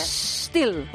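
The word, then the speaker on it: Stihl: Ay, qué bien lo has pronunciado. – ¿Verdad? – A ver, repite. – Still. – Ole. – Still. ¿Cómo es? Sin la E, porque es una S, ¿cómo se escribe? S-T-H-I-L.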